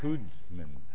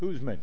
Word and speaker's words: Kuzma: Kudz. K-U-Z-M-A. Kudzman. Don't pronounce it right. – I got the Z. Kudzman.